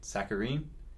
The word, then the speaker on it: saccharine: She's overly sentimental and incredibly, what's the word, saccharine? Is that how you pronounce that word? – Yes, it is. Well done.